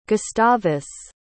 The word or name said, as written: gustavus